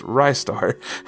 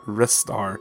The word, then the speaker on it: Ristar: Brody Peddle says, I learned on Player One podcast this week that it's pronounced Rystar, as in Rising Star, not Ristar, as I had always thought, and certainly not Restar, as some obnoxious people might claim. Oh, man.